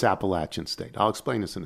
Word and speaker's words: Appalachian: The correct pronunciation of the university is a short A in the middle. Appalachian. Appalachian State.